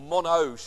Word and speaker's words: Monod: 30 years ago, the Nobel Prize-winning biochemist Jacques Monod, or Monod, should I say, sorry, my French pronunciation is slightly askew.